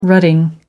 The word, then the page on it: rutting: American English